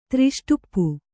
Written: ತ್ರಿಷ್ಟುಪ್ಪು

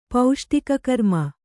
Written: ಪೌಷ್ಟಿಕಕರ್ಮ

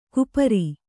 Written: ಕುಪರಿ

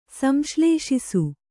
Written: ಸಂಶ್ಲೇಷಿಸು

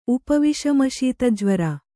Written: ಉಪವಿಷಮಶೀತಜ್ವರ

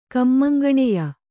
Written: ಕಮ್ಮಂಗಣೆಯ

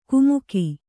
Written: ಕುಮುಕಿ

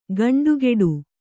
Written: ಖಂಡಗಟ್ಟು

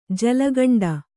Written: ಜಲಕಂಠ

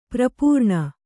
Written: ಪರಿಪೂರ್ಣೆ

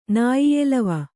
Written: ನಾಯಿಯೆಲವ

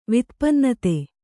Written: ವಿತ್ಪನ್ನತೆ

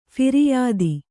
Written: ಫಿರ್ಯಾದು

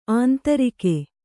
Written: ಆಂಧ್ರಕ

